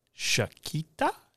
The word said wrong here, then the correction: Shakti: No, I think it's Shakti. – Shakti? I'm awful at pronouncing things, by the way.